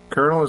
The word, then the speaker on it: colonel: Colonel is pronounced with a R sound, but there is no R in the spelling of the word. The word colonel is an alteration of coronel from the Middle French.